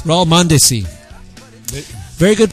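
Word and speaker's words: Mondesí: How did you pronounce his last name? – Mondesi.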